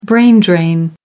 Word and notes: brain drain: American English

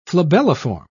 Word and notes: flabelliform: British English

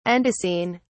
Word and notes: andesine: British English

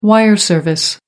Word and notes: wire service: American English

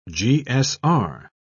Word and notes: gsr: British English

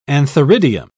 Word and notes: antheridium: British English

